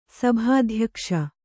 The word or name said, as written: ಸಭಾಧ್ಯಕ್ಷ